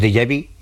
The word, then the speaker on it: déjà vu: lo que llamamos déjà vu, que eso, mira que bien lo he pronunciado. – Sí, muy bien.